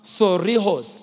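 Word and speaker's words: thureos: That word shield in the Greek, it's the word T-H-U-R-E-O-S. T-H-U-R-E-O-S, pronounced thorios. Thorios.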